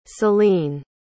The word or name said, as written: selene